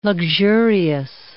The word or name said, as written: luxurious